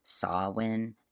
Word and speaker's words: Samhain: And by the way, if you're listening to this, that name Samhain is spelled S-A-M-H-E-I-N. So it's spelled a little differently than you might expect for the pronunciation.